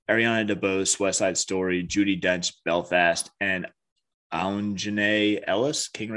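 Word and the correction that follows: Aunjanue: I think it's Anjanae or on. Yeah, it's Anjanae. – Anjanae.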